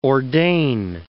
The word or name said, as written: ordain